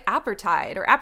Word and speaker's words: apartheid: I've never known actually how to say that, in South Africa. – Apartheid. Yeah. – So did I say, I said it wrong both times because I never say it out loud.